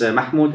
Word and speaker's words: Mahmoud: And my name is Mahmoud, as you said it correctly.